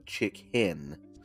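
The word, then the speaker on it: Chichén: Yeah, I'm sure I'm saying it all wrong, but it is spelled Chichen. So, not like chicken, but Chiquin.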